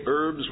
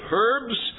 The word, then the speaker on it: herbs: Some of us still say herbs without pronouncing the H. If you feel more comfortable pronouncing the H and say dinner of herbs, that's your liberty.